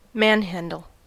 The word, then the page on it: manhandle: American English